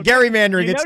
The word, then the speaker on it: gerrymandering: Because my wife is a direct descendant of Elbridge Gerry. And that's how you pronounce it. It's actually gerrymandering.